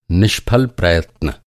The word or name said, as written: निष्फल